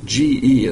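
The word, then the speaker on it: ge (γῆ): The word here is ge, G-E is how it's spelled, pronounced ge.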